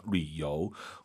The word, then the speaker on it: 旅游: 유땡땡, 류. 류라 발음합니다. 삼성이성. 류요.